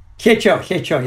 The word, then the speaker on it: Hyecho: Hyecho, yeah.